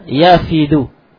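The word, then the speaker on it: يَفِدُ: Lianna ba'd as-sahabati, kan sunnya sebagian sahabat, kana yafdu kana yafidu, ini bacanya kana ya yafidu, wufud, ini wufud, nah wufud yafidu, kana yafidu ala nabi sallallahu alaihi wasallam.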